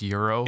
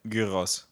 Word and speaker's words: gyros: Like people have told me that you don't pronounce the G. It's pronounced euro. – It's gyros, actually. That's how you pronounce it.